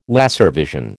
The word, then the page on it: laservision: American English